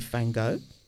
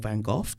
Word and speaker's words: Van Gogh: with Van Gogh, or Van Gogh, depending on your pronunciation.